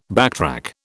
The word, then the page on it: backtrack: British English